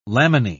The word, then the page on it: laminae: British English